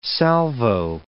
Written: salvo

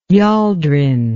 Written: yoldring

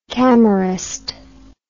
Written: camerist

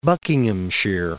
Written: Buckinghamshire

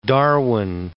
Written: Darwin

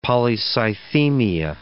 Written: polycythemia

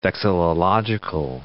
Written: vexillological

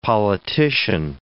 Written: politician

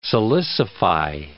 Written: silicify

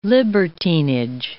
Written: libertinage

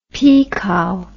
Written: pikau

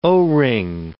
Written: O-ring

